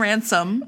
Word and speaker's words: Ransom: Kieran Ransom, which I've been saying all morning, rhymes with handsome.